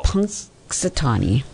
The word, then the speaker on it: Punxsutawney: I didn't realize there was an N at the beginning. I guess I never pronounce it, so... – Punxsutawney, Phil. – Punxsutawney.